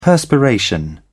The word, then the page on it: perspiration: British English